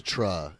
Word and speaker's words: Citra: Citra, it's pronounced.